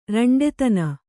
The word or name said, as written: ರಂಡೆತನ